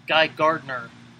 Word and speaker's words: Gardner: a gardener technically has an extra syllable. Guy Gardner is G A R D N E R.